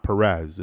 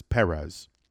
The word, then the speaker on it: Perez: For Yuri Perez, and just so you know, I'm not saying Perez wrong. That's how you pronounce his last name. It's not Perez. It's Perez. I don't want anyone out there to think I'm an idiot. Like, what is saying? It's Perez. No, it's Perez.